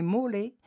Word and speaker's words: Mole: En italien, le mot Mool, prononcé mole, désigne un monument de proportion monumentale, colossale et imposante.